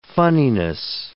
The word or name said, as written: funniness